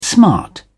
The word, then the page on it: smart: British English